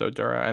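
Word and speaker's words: Oduro: Now, they just brought in Josh Odoro from George Mason. I hope that's how you pronounce it. – Yeah, it's Odora. And then they got Justin Fernandez coming over from the Patriots too.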